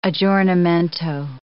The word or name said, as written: aggiornamento